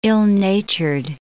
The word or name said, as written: ill-natured